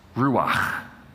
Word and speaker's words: ruach: It's pronounced ruach. Got to get in the back of the throat, okay? Ruach.